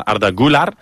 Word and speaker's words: Güler: Arda, s'escriu Güler, es pronuncia Arda Güler. Qui és aquest futbolista? – Güler, li diem Güler.